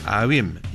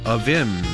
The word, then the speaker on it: Avvim: 먼저 아윔, 영어로는 이렇게 발음합니다. – A-V-V-I-M, 특이한 스펠링이에요. 아윔, 올해 한번 잘 듣고 따라해 보시기 바랍니다. 아윔, 한번 더요.